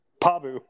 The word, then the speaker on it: Pabu: P-A-B-U. – P-A-B-U. – I mean, honestly, it's like Babu Frick, but with a P.